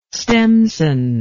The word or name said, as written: stemson